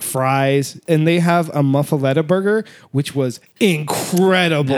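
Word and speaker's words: Muffuletta: Muffaletta. – Yeah. I know I never can pronounce that right. Thank you, Dan from Louisiana. – You said it right.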